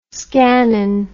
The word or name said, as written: scanning